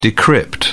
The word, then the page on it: decrypt: British English